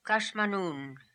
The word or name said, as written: Kashmanoon